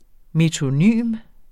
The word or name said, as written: metonym